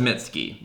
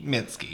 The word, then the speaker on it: Mitski: Mitski's latest album, which for a second I thought was Mitski. I thought, like, it was a fun pronunciation game, but it's just Mitski. – Mitski, yeah.